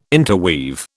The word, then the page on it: interweave: British English